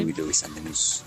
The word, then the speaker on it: Huey Lewis and the News: Huey Lewis and the News.